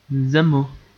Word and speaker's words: Zamo: Zamo.